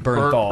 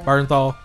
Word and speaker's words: Bernthal: To I like John Bethany. I think I said it right. – You mean Bernthal? – Bernthal.